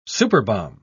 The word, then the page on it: superbomb: British English